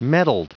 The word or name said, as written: mettled